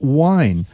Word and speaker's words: wind: And hundreds of years ago, I guess in the time of Shakespeare, they use the expression or actually the pronunciation of wind as wine. So it sounds like W-I-N-E, wine.